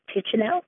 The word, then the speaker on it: Kitchenow: Hello, my name is FJR Kitchenow. A lot of people balk at saying my name because it's one they've never seen before, but it is boringly phonetic, just the way it looks, Kitchenow. No one seems to know where it comes from exactly.